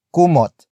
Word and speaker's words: kumot: And that is kumot. Kumot. Kumot. Kumot is spelled as K-U-M-O-T.